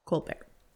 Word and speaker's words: Colbert: Claudette Colbert. Colbert, like Stephen.